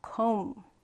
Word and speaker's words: comb: comb. comb. 자, 지금 비 발음했어요, 안 했어요? 하지 않았어요. 그냥 comb이라고 하고요. 요 b는 발음하지 않는다라는 거 유의해 주셔야 돼요.